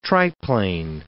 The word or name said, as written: triplane